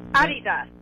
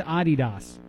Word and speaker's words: Adidas: So you can back up what Blake is saying, that you have relatives as well that pronounce the word Adidas. – Adidas. – Okay, where are your relatives from? – My mom was born in Austria. – Okay. – And see? – So every single one on that side of the family says Adidas. – No, yeah, Adidas, yeah. – Okay, all right, yeah, right, I'm still saying it wrong.